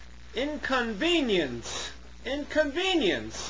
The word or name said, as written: inconvenience